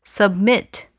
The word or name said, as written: submit